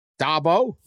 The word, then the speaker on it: Dabo: Did I say it right? – Yeah. – Dabo? – Yeah.